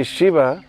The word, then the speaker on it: Shiva: It's actually Shiva. Our Lord Shiva is a very short eye. If you look, it's short eye. It doesn't have a line over top of it.